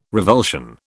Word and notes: revulsion: British English

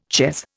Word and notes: chez: Indian

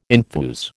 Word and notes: enthuse: American English

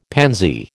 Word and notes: pansy: American English

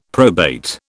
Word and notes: probate: British English

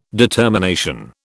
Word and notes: determination: British English

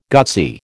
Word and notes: gutsy: American English

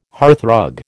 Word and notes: hearthrug: American English

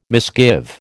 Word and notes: misgive: American English

